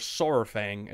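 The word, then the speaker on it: Saurfang: Verok Sourfang, or Sourfang as it's supposed to be pronounced, is a legend amongst the Horde, and a lot hold him in high regard.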